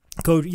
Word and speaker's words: Koji: Koji.